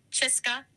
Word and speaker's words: Česká: Y se pronuncia aproximadamente Česká República. Detalles. Česká. Che suena como che de chico.